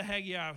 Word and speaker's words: Haggai: Haggai, listen to Haggai.